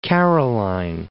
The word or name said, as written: Caroline Islands